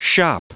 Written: shoppe

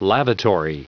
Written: lavatory

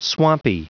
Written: swampy

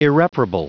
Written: irreparable

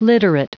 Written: literate

